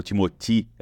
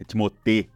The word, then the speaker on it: Timothée: Eu sempre falei errado, falo Timothée, é Timothée Chalamet.